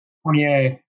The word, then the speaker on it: Fournier: How do you say Fournier? And Fournier, there we go.